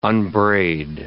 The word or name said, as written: unbraid